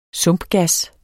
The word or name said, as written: sumpgas